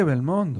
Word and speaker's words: Belmondo: Pero Belmondo, me equivoqué, Belmondo.